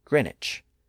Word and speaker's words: Greenwich: The same thing happened with the name of the town of Greenwich, which became Greenwich. It's spelled G-R-E-E-N-W-I-C-H, but we don't pronounce the W today.